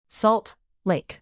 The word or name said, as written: salt lake